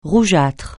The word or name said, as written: rougeâtre